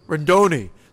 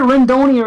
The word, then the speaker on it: Rendon: is it Rendoni or is it gonna be Rendon? – No, because I think I said it wrong. I said it like you did, in one of the TGIF episodes, and then he texted me, he said it's like I think he said like macaroni.